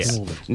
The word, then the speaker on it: St Albans: It's St Albans. Pronounce it correctly. – St Albans. St Albans, like Pran. – St Albans. – Yeah. Snorbans.